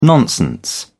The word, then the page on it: nonsense: British English